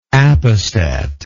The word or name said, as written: appestat